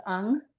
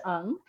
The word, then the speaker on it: Ng: I am at, my name is pronounced Stacy Williams Ung. my last name is Williams hyphen NG, and it's because my Asian husband has an unusual name, but it's pronounced Ung.